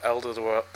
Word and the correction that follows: Alderweireld: Toby Alderweireld.